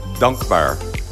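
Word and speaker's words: Dankbaar: I'm Hadass Kuznets at 1911 East Passyunk Avenue with the owner of Dunk Bar. Am I saying that right? – Dunk Bar? It's like a longer A. Dunk Bar.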